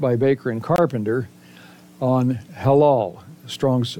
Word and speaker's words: halal: And it's transliterated into the English word halal, halal. It's spelled H-A-L-A-L, pronounced ha-lal.